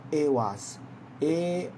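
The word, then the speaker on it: Ehwaz: OK，好，接下来的话呢，我们来看一下的话呢，这个这个也是他简写了，真正的话呢是叫 A was，A was，，E I H，W A Z，OK，E I H，W A Z。有人讲说他最喜欢 Yera，对呀，Yera 是一个好牌。你们有喜欢的牌吗？你们可以告诉我。 Yera，OK，这个是 A was，OK，这个是 A was，A was 的话呢，代表说有东西在保护你。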